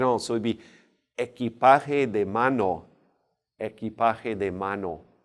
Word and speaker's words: equipaje: And your luggage in general is going to be el equipaje. Equipaje, remember that Q, U sounds like a K.